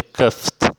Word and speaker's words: coughed: Então, cuff, o passado de cuff, escreve C-O-U-G-H-E-D, mas se lê cuffed.